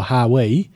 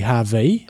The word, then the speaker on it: Hawi: Hawaiians often pronounce the letter W as a V. So you said just before Hawi. – Yeah. – And it's supposed to be Ha-V.